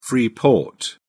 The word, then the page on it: free port: British English